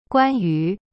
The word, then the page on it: 关于: guan yu